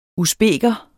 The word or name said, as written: usbeker